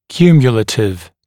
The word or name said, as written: cumulative